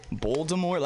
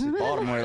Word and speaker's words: Baltimore: Told them I was from Baltimore, and they're like, so how do you say it? Baltimore? Like, no, you're trying too hard, man. It's Baltimore.